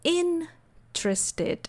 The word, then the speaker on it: interested: Mas em inglês fica mais ou menos como in interested. A primeira sílaba é mais forte e mais longa. Interested, interested.